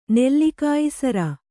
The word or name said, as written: ನೆಲ್ಲಿಕಾಯಿಸರ